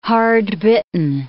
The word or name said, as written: hard-bitten